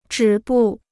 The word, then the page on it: 止步: zhǐ bù